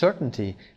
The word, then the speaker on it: certainty: Is now an absolute certainty. دیگه اطمینان مطلقه دیگه از این قضیه. Certainty تلفظش هست. Certainty نمیخونیم رو.